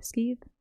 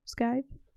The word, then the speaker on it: scythe: Is that what it's called? Scythe? Skeeve? Scythe? – Scythe. – There you go.